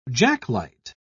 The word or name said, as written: jacklight